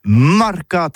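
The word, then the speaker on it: marcat: Eh bien, d'ici à tout, mais le mercat de l'Uni ou le mercat des rebelles, mais en gascogne toulousano, c'est prononcé sur les marcats, avec un A marquette.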